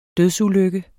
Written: dødsulykke

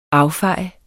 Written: affejet

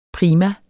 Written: prima

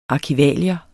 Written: arkivalier

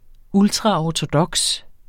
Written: ORTODOKST

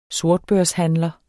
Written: sortbørshandler

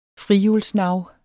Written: frihjulsnav

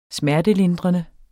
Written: smertelindrende

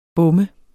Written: bom

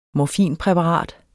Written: morfinpræparat

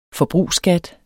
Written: forbrugsskat

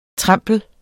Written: TREMPLEN

